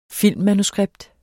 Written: filmmanuskript

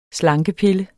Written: slankepille

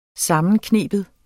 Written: sammenkneben

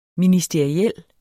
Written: ministeriel

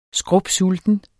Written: skrupsulten

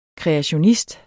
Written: kreationist

